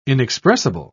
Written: inexpressible

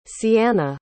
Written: seanna

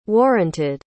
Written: warranted

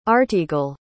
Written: artegal

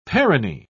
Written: perone

